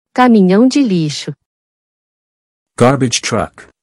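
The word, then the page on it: Caminhão de lixo: Portuguese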